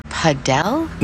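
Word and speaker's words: padel: Wait, is that Paddle? – No, that's paddle. Rhymes with saddle.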